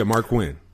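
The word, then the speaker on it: Marq Quen: Yeah, Mark Quinn.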